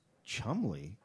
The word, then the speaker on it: Cholmondeley: Oh, it says right here, pronounced Chumley. What? – It's Cholmondeley, pronounced it's, Chumley. – It's literally spelled C-H-O-L-M-O-N-D-E-L-E-Y, and it says pronounced Chumley.